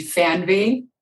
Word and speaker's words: Fernweh: Well, in the German version of saying it would be Fernweh.